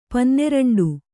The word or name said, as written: ಪನ್ನೆರಂಡು